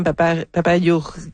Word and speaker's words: Papageorgiou: D'ailleurs, on a reçu Madame Papageorgiou. Voyons, j'ai de la difficulté à me souvenir de son Giorgio. Nom. – Papageorgiou, c'est comme ça qu'il faut le prononcer.